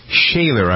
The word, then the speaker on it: Schoeller: The story from Missouri about a young fellow named Shane Shaler, I believe is how you pronounce his name, S-C-H-O-E-L-L-E-R.